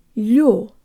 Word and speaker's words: лё: Now the first syllable. Let's imagine for a moment that the word consisted only of the л and the ю. It would sound like this. Лё, лё. The л is soft, so the tongue is high and forward. There's almost a y sound built in, and the tongue moves from very high to very low in order to pronounce the full stressed o sound.